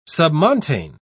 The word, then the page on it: submontane: British English